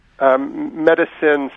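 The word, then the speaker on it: Metacine: Medicine, spelled with a T, right? It's M-E-T-A-C-I-N-E, sort of a play on medicine.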